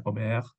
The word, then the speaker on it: Aubert: se escribe Aubert, se pronuncia Aubert, los rusos escribían en ruso Ober, O-B-E-R.